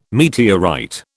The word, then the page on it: meteorite: British English